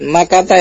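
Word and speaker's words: Makkata (مكةَ): Min Min Makkata ya, Dad? – Min Makkata. Karena dia apa? – Ghairu munsharif ya, Dad? – Kenapa? – Nama kota ya, Dad?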